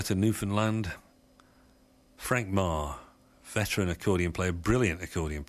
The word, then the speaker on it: Maher: And Frank Marr put out an album called Marvelous, spelled M-A-H-E-R, hence the sort of bleating in the pronunciation. But anyway, it's Frank Marr, and this is a medley of tunes from the album Marvelous.